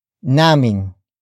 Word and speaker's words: naming: Naming. Naming. Naming is spelled as N-A-M-I-N-G.